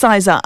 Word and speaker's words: Sizar: So I'm glad you pronounced it properly, because I haven't been pronouncing it properly. But it's Sizar.